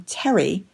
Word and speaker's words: Teri: Not surprisingly, my young cousins had trouble pronouncing this mouthful of a name, so I became Terry, spelled T-E-R-I, to all my family and friends.